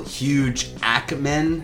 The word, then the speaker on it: Huge Ackman: You can also Hugh, or as a friend of mine likes to call me at school, Huge Ackman, with the accent on the A.